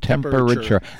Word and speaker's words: temperature: I told you, four syllables. That's all. Broadcasting School Lesson 101, temperature. The other one is F-A-M-I-L-Y.